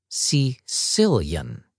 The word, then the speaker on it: caecilian: You say it like this: caecilian.